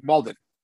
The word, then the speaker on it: Malden: Malden. Thank you. Malden.